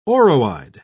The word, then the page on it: oroide: British English